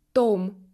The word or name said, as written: tome